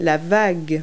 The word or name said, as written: wave